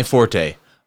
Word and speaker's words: forte: Yeah, forte is the musical, because it's a musical term. – Okay. – F-O-R-T-E with the accent. That's forte. That is musical. When you're talking about a gifting or something you're interested in, it's actually pronounced fort. Nobody pronounces it that way, but that is the actual pronunciation.